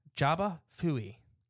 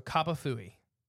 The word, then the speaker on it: Jaba Fui: Jaba is also correct depending on where you're at. – Okay. – It's spelled the same way either way. It's spelled with a J in the front. – It's just okay. – It's pronounced either Kaba or Jaba.